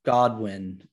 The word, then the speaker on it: Goodwin: And a lot of it is reflections on the work of some Puritans, Thomas Godwin, spelled Goodwin, but I think it's pronounced Godwin,